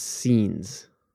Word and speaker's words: seines: I'm gonna blow the pronunciation because it's S-E-I-N-E-S. It's scenes. Yes, that's how you say it. Scenes. S-E-I-N-E-S.